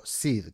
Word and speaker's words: seed: Uno de esos parámetros es la semilla o SID, que se escribe S-E-E-D, ¿vale?